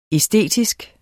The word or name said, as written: æstetisk